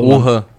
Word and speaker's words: Wuhan: Wuhan. – Wuhan, né? É. – E a, W-O-H-A-N.